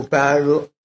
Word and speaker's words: 般若: 以一切知其般若波罗蜜，般若是梵文的一个读音，梵文的这个读两个字啊，不要我们这个一般讲的般若，不要读般若，读般若。这个翻成中国字的意思啊，就是智慧。般若这两个字啊，含义广泛，勉强的翻译找一个重要的这个名词啊，就是智慧。